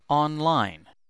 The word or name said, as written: online